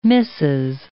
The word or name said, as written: Mrs.